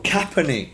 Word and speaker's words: Kaepernick: Who the hell's Copernic? – About pronouncing it right. – Who is he? – The one that took the knee in Black Lives Matter. In the NFL player. – Oh, Kaepernick.